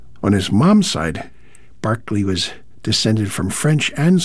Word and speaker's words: Berkeley: The English composer, Sir Lennox Barclay, that's spelled like Berkeley, but Barclay like Barclay Square, born 1903, aristocrat on both sides of the family, although there's a question of some legitimacy on his pop's side.